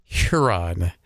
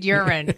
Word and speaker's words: Huron: Please pronounce the H on socials. – When you don't. Sounds like you're saying Lord Urine.